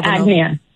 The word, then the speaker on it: Agnia: How do you pronounce her name? Agnia Galdanova. – Yeah, Agnia. Yeah, Agnia Galdanova.